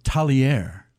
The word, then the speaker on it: Tagliare: How did you attract Tagliari to come there? I mean, that's a great one. – So let me correct you because they corrected me. It's pronounced Tagliare.